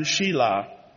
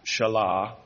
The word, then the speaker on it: Shelah: since I did not give her to my son Shelah, which really should be pronounced Shelah, sorry, and he did not lie with her again.